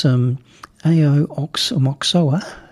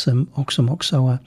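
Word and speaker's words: Aoxomoxoa: It was A.O. Oxomoxoa. But in actual fact, you don't pronounce the first A, apparently. It's Oxomoxoa. But this is a track called Saint Stephen.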